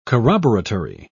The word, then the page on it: corroboratory: British English